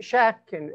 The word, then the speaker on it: Schatkin: Schatkin, S-C-H-A-T-K-I-N.